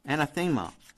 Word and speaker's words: anathema: Anathema.